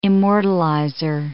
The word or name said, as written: immortalizer